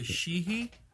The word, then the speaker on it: sidhe: The Sheehy? – Yeah, that's the word for the ancient evil fairy folk. A long-standing really? – Term, yes. – Oh, okay. – It's spelled S-I-D-H-E.